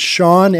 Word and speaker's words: Sean: If you want to get a hold of me, it's Sean, S-E-A-N.